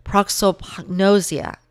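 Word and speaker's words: prosopagnosia: Because you actually have a condition, it's called face blindness, which the scientific term for that is proxopagnosia.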